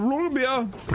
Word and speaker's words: Roggenbier: We're debating on how to say it, and it's like, just run it together. Oh, yeah, have a Roggenbier. – Roll the R and say it like Dieter.